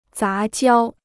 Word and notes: 杂交: zá jiāo

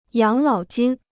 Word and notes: 养老金: yǎng lǎo jīn

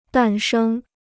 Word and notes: 诞生: dàn shēng

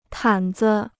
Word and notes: 毯子: tǎn zi

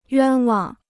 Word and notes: 冤枉: yuān wang

